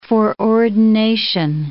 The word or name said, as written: foreordination